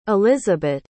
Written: elisabet

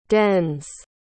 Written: dens

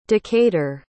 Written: decatur

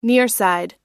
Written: nearside

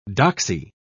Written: doxy

